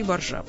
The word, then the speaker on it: баржа: Словарь иностранных слов. 2 варианта ударения – баржа и боржа. Словарь лексических трудностей. 2 варианта – баржа и боржа.